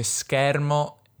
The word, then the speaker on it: schermo: Lui dice schermo e scherno con due E belle aperte, un po' come le direi io, ma in realtà giuro, non è piemontese Caparezza affatto, è pugliese, quindi però usa in questo caso anche qui due vocali aperte.